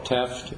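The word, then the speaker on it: Tefft: My name is Carvel Teft, and the name is spelled T-E-double-F as in Frank, and then T again.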